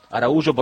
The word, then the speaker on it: Araújo: Bueno, final del primer tiempo entonces, de Miami, Uruguay 2, Araújo o Araújo, Araújo porque me quedo con la pronunciación portuguesa, que es el origen de ese apellido,